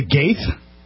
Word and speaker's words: Goethe: Anyway, there's not a single R in that word, and it's pronounced Goethe.